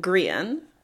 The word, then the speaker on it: Grian: So the projection is of the dragon Grian, which is how you say that according to my research, which means sun. So fitting. – Like the solar sun? – S-U-N.